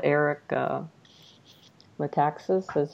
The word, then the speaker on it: Eric Metaxas: Eric Metaxas. Did I say that right? – Yeah, that's correct.